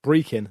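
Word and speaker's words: Brechin: Please tell Luke it is pronounced Brecon, not Brecon, as in Wales."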